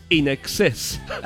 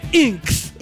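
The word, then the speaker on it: INXS: Yo tengo un vinilo de In Excess, que era de mi tío, y tenía un sticker que te pone, se pronuncia In Excess. – Ah. – In Excess. In Excess. Porque la gente decía Inks.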